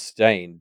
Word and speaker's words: Steyn: Or I guess it's pronounced Stain, because Fernanda in all of the chapter misspells it like stain as in like food stain, whereas it's spelt S-T-E-Y-N.